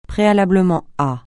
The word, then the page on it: préalablement à: francais